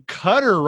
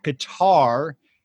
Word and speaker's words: Qatar: Patti is over in Qatar right now. I know a lot of people in our country mispronounce that Qatar. It's really pronounced Qatar.